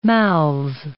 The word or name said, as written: mouths